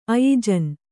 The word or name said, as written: ಆಯೋಜನ